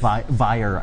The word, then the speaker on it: Vyre: So the question is, are we going to do anything more with the City of Rye from Hell's Rebels? – City of Vyre, I think. – Vyre? – Is that how you say it? – Yeah, V-Y-R-E.